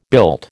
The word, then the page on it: built: American English